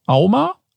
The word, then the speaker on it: AUMA: Und die Auflösung ist, im Jahr 2022 wurden laut AUMA, A-U-M-A, richtig ausgesprochen? – Ja, wurde richtig.